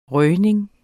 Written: røgning

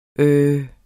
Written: øhm

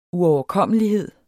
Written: uoverkommelighed

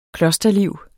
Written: klosterliv

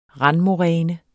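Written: RANDMORÆNE

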